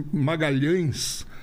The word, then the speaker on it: Magalhães: E como a Itália era o lugar onde eu ia mais fazer as apresentações, um empresário italiano virou e disse Magalhães. Ah, era... Magalhães na Itália não pronuncia por causa do LH. Maga...